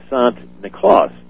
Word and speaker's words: Sant Niklaus: But the Dutch pronounced St. Nicholas Sant Niklaus. And so if you say Sant Niklaus 3 times fast, Santa Claus, you go, oh, Santa Claus. So that's where our pronunciation of Santa Claus came from, was the Dutch.